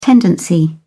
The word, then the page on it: tendency: British English